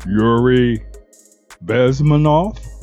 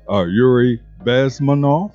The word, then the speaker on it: Yuri Bezmenov: Again, that is Yuri Bezmenov. I do apologize for butchering his name the first time around. Yuri Bezmenov. Speaking again in 1985, point out a couple of things he mentioned in this piece.